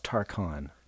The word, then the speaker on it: Tarkine: So it's pronounced Tarkon, I guess.